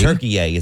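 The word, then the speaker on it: Türkiye: They are now Turkey-A. – Turkey-A. Is that how you pronounce it? – Turkey-A. – Turkey-A. – Turkey-A. USA versus Turkey-A. – It doesn't look like that when you spell it, but I...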